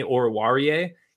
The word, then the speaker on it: Oruwariye: I learned how to pronounce his name, Amani Oruwariye.